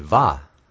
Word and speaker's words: Vav: Well, it would be Vah, V-A-H.